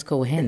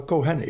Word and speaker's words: Cohen: Of zoals we hier zeggen, Leonard Cohen. – Ik geloof dat de Joodse uitspraak wel Cohen is, hè?